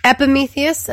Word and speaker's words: Epimetheus: Epimetheus and Prometheus, yes.